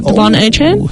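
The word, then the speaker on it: De'Von Achane: I'm going to start off with DuVernay Chen. Devon A. Chen. – Nice. – He would like his name pronounced.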